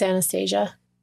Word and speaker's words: Anastasia: Or Anastasia. – No, it's Anastasia.